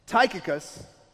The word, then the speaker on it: Tychicus: And there's this guy by the name of, let me make sure I pronounce it right, Tychicus, who comes, and he's a brother, and he's a faithful minister, and he's one of Paul's helpers.